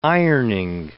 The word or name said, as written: ironing